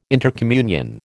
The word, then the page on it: intercommunion: American English